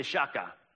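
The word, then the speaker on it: shachah: Everybody say shaka. It's kind of like Shaka Khan. Spelled with an S, though.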